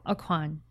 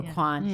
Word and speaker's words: Aucoin: Kevin Aucoin, is that how you say it? – Aucoin. – Thank you, Aucoin.